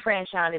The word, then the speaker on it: Franchon: Suite Franchon is my name, spelled like a hotel suite, but it sounds so sweet like candy.